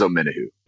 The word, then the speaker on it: Omenihu: And Bob, am I saying that right? Is it Amenahu? Yes. Is that how you say it? Yes, absolutely. It's a uh, very confusing. There was a lot of controversy. No, not really. It's yeah, it is Omenihu.